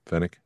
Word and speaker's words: Fennec: Anik Shan, right? – Yep. – Phoenix yep. Shan? – Fennec.